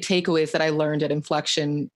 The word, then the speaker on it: Inflexion: Inflexion, yep.